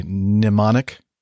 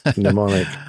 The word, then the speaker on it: mnemonic: There, all these words are words that start with a certain letter, but they don't really sound like that letter at all, or the letter is silent. Pneumatic for P. Let's see. Mnemonic for M.